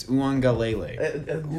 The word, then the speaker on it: Uiagalelei: Yeah, and I did say it right. It's Uiagalelei.